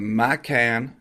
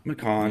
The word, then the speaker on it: Macan: Give Cayenne the Macan. I'm saying that wrong. Macan.